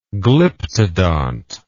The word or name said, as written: glyptodont